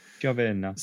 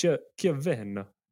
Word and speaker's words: Chiavenna: Don't know how you say it. – Chiavenna. – So it's Chiavenna.